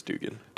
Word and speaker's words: Duggan: Am I pronouncing that correctly? Duggan or Dugan? – I think it's Dugan. – Dugan, okay.